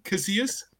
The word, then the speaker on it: Casillas: How do you pronounce it? – Casillas. Abdullah, you are pushing for that brick door, bro. You are listen. You're making a late push for that brick door, but Okay. – He's not American. – Have I said it correctly? Casillas? – Yes. Casillas. Okay, so continue.